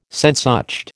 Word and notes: Sehnsucht: American English

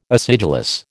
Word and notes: acidulous: American English

